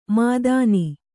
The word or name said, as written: ಮೇಥಿನಿ